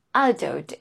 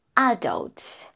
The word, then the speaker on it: adult: Adult is British English for American English. This A is pronounced a, short er, and we stress the second syllable. So, adult, adult, adult. Okay, so again, British English, adults, and American English, adults.